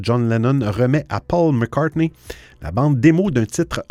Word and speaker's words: McCartney: Aujourd'hui, c'est la légende britannique de la chanson, hein, à savoir Sir Paul McCartney. Euh, lui, je prononce son nom correctement parce que c'est cela,